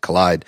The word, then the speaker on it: Kolide: Actually, I learned that I mispronounced their name among many others, but it looks like collide, K O L I D E, but they pronounce it collide.